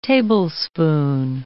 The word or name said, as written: tablespoon